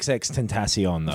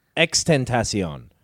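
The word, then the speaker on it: XXXTentacion: It's not XXX Tentacion, though, is it? – It isn't it? – It's X-Tentacion. – But there's three X's. – There's three X's, but you don't pronounce all three, so you've learned fucking nothing from this.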